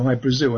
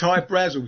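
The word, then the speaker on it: Hy-Brasil: High Brazil, people pronounce it High Brazil, and it's... – Brazil, okay. – But it's High Brazil because that's how the Irish would pronounce it, because it's an island that's off the southwest coast of Ireland.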